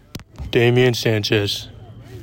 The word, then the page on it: Damian Sanchez: DAY ME in SAN chez